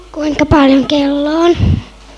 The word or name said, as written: kello